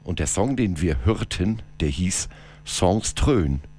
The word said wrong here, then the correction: Drön: Und so ich das jetzt richtig ausgesprochen habe, ich nehme es aber mal an. – Drön. – Drön, Songs Drön, das hat ganz schön gedröhnt.